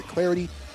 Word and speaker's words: Clardy: You can follow me on Twitter at Troy Clarity. Last name is spelled C-L-A-R-D-Y.